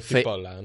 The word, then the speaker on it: Cipolla: Cipolla, no c p C-I-P-O-L-L-A. – Bueno, será Cipolla, pero... Cipola, no sé.